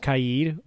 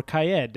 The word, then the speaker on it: Kaid: And then Kaid, which turns out his name is pronounced Kaid or Kaed, depending on which Ubi employee you listen to.